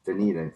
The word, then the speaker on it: Dunedin: But yeah, the Blue Jays are going to be away from Dunedin. I think is that how you pronounce it? – Dunedin.